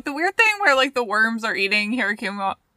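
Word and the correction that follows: Hyakkimaru: I fucking just butchered his name. – Hyakimaru. – Hyakimaru. Hirakimaru. What the fuck was that? Hyakimaru.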